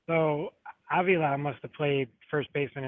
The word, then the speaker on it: Avila: Alex Avila, Dan, or how do you pronounce that again? – Yeah, you're Avila. Yeah, you were right.